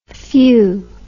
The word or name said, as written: feuar